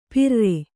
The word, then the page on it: ಪರಾರಿ: parāri